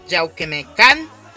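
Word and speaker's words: Yauhquemehcan: Ángel Meneses, que nos está escuchando desde Yauquemencán. Eh, Sí, Está bien pronunciado, Gabo.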